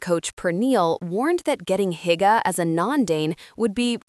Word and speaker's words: hygge: My Danish education began with hygge, pronounced hygge, an untranslatable word meaning more than cozy or comfortable.